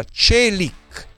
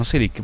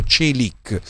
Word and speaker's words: Çelik: si pronuncia Çelik, Çelik, tutti dicono Çelik, però ha detto Çelik.